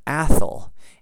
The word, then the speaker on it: Atholl: A-T-H-O-L-L, Athol, in case the exact pronunciation of that does not come through very well in this microphone and you think I'm saying something else. That's why I spelled it for you.